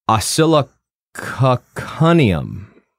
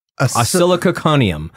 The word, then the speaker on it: Oscillococcinum: Osilococcaneum. Osilococcaneum, OSCILLOCOCCINUM.